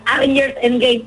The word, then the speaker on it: Avengers Endgame: Fíjate que te cuento que van a relanzar otra vez la película de, a la película de Avengers Endgame.